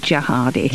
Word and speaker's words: Gerhardi: Well, it's a soft G, actually, Gerhardi.